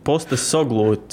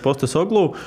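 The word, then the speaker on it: Postecoglou: Kā viņi pareizi izrunā? Māki. Es mēģināšu. Angijs postes solo, solo, solo klub. Poste postes oglū, cik es saprotu, soglu, pareizi. Jā, Vismaz nu. komentētāji tā saka, postes oglū.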